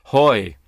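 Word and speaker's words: hoy (הוֹי): The Hebrew word is hoy, rhymes with boy, all right?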